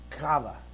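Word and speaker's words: qavah: but I'm going to say it's kava. It's Q-A-V-A-H.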